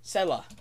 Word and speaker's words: Selah: I don't know how to say it. I think it's Say. How do you say that? Say. Huh? Selah. That's how you say it.